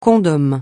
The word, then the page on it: condom: francais